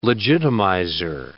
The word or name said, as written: legitimizer